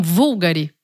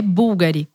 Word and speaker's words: Bulgari: Tem pessoas que falam vulgari, porque o U ele é usado com o V. O V, fiquem pensando nisso. O logo bulgari é com V. Mas a pronúncia é búlgari, com U, certo? – Exatamente.